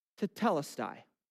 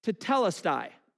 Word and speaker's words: Tetelestai: And it's pronounced this. Tetelestai. So I want you to repeat that after me. Just say it out loud. Tetelestai.